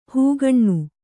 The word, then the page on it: ಹಗಣ: hagaṇa